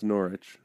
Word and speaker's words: Norwich: It's pronounced Norwich.